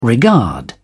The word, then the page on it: regard: British English